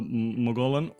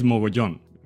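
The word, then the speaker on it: Mogollón: Yes, my name is Nando Mogolan, or Mogojon, as I will pronounce it in my native Spanish language. Not from Spain, though. I'm from South America in Colombia, but yeah, it is Mogojon.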